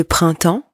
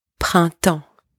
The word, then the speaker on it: printemps: First, do not pronounce the letter P and the letter S in the word printemps. It's not le printemps, okay? Le printemps. The P and the S are silent here.